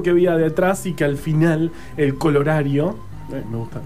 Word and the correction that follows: corolario: no, colorario. No, corolario. ¿Cómo se dice? Eh, corolario.